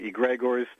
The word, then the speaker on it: Egregores: Nobody gets it right, so don't worry about it. It's Greek. – It's Greek. – We say egregores, although some say egregores.